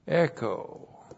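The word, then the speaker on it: echo (ἔχω): Echo. E-C-H, long O, echo.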